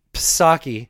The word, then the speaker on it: Psaki: Psaki, remember the P is silent like the P in swimming Jen Psaki talking about uh, prices and her understanding of economics.